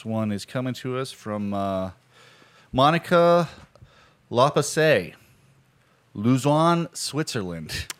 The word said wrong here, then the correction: Luzern: Am I saying that right? – Yeah, Luzern. – Luzern.